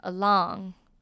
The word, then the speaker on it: along: 那他们的发音有些微的不一样哦，我来把两个都念一次，单独 alone，沿着 along Alone, along，这样大家有没有分清楚了呢？